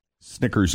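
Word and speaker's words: SNKRS: The retail price is $120, and Nike is going to release them on their Snickers app on Saturday, but for now they're selling them at random pop-ups at basketball events in big cities.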